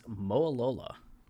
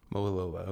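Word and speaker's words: Mowalola: It was a bunch of syllables, and I was wondering if you knew how to pronounce this name. – It is Moalola. – Moalola, okay.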